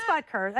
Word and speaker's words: Butker: Can you read Yeah, it is Butker.